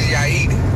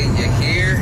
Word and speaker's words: Yair: Called, my name is Yair, but white people always say Yair. Like they always say something different.